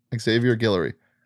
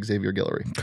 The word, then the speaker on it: Xavier Guillory: Xavier Guillory. X-A-V-I-E-R space G-U-I-L-L-O-R-Y Xavier Guillory.